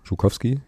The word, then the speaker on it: Zhukowski: Also das Z in Zhukowski spricht man wohl wie das zweite G in Garage. So, also Zhukowski, genau.